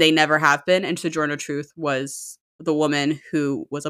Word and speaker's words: Sojourner Truth: How do you say her name? – Sojourner Truth.